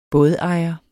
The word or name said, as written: bådejer